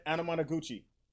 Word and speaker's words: Anamanaguchi: Anamanaguchi.